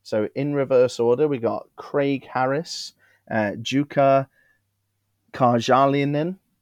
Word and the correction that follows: Jukka Karjalainen: You butchered that. – Jukka Karjalainen. I think that sounds Finnish to me. – Karjalainen. – I've given it a right go. Karjalainen. That's probably right, isn't it? Jukka Karjalainen. – Yeah. – Good job.